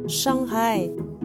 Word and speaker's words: Shanghai: Et vous l'avez peut-être remarqué, on prononce la lettre H en Chine, donc Shanghai est la bonne prononciation.